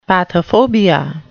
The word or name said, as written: pathophobia